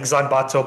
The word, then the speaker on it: Zanbato: A- apparently, in like uh, it's it's like Zanbato bato, like just the way that it's pronounced in Japanese.